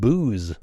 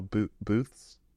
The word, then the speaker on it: booths: I thought you said booze. – No, booths. – Booths. – Did I say it right? – You said it right.